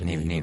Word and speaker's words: Neve: L'altro giorno, se ricordate, abbiamo visto questo banco gigantesco, bravissimo, Neve o Niv? – Niv. – Si pronuncia Niv. Bravo. – È inglese. – Che studiato, è Niv. E quindi, insomma, abbiamo un po' parlato un po' di questo.